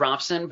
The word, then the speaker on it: Robson: It's Robson, but Robson is a long, we have a long history of people pronouncing it Robson, so it's okay.